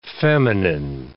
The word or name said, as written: feminine